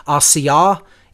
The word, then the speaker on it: Asiyah: Slightly off on the pronunciations, but I'm not going to, hold it to that, level of specificity. I would say if I were to give the correct pronunciations as closely as I know them to be, it's Asiyah, Yetzirah, Briah, and Atzilut.